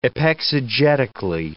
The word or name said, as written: epexegetically